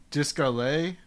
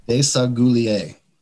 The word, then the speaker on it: Desaguliers: You mentioned this Discalet. Is that how you pronounce that? – Desaguliers.